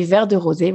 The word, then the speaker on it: Vert de Rosée: Donc, ça s'écrit V-E-R-T-D-E-R-O-S-E-E. E e e. Et ça, c'est Vert de Rosé.